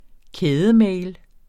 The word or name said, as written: kædemail